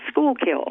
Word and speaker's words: Schuylkill: And that's pronounced Schuylkill.